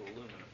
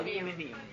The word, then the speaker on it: aluminium: The English also add a syllable to aluminum, so. – Yeah. – I was just curious. I didn't know. – Aluminium.